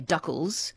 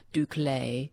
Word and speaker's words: Duccle: Some people say duckles because it's spelt D-U-C-C-L-E. Some pronounce it the French way, Duclaes.